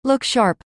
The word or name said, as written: look sharp